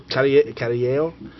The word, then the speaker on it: Carrillo: For artists, Roger Landridge, Dan Close, Matt Kint, Aaron Reiner, Sergio Carrello, Fred E. Williams II. Did I say it wrong, Sergio? – It's like Latin. – Carrillo? – Carrillo? – Yeah, there you go.